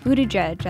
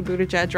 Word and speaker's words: Buttigieg: They want to see South Bend, Indiana Mayor Pete Buttigieg at the soapbox. Laura, Harris, and Elle all sat down in our studios for an interview. Harris is a big fan. Um, he was able to say Buttigieg right from the start when you know people couldn't pronounce it.